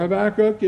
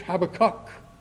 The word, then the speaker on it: Habakkuk: Let us turn to the Old Testament, to the prophecy of Habakkuk, or Habakkuk if you prefer. Habakkuk or if you want to be real strict on the Hebrew language, Habakkuk, with the stress on the last syllable.